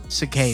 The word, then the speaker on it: Cicada: Dark Web Cicada.